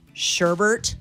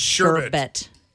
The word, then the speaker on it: sherbet: Sherbert? – Sherbert. It's sherbet. – Oh, come on. – There is no R in there.